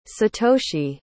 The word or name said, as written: satoshi